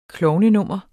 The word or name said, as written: klovnnummer